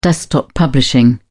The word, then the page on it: desktop publishing: British English